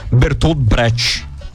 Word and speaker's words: Bertolt Brecht: Foi sobre o dramaturgo, encenador e poeta alemão Bertolt Brecht.